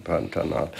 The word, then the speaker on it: Pantanal: Pantanal, genau, da muss ich aufpassen beim Aussprechen, Pantanal.